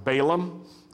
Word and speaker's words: Balaam: And this was actually the case for both Balak, who was the king of Moab, and Balaam, or as we say in the South, Balaam.